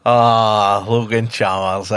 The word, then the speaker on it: Logan Chalmers: This player's last goal for us was in a 6-0 win against Morton, and his name rhymes with Brogan Clammers. – Logan Chalmers, eh? – There we go.